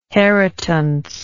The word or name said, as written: heritance